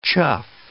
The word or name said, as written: chuff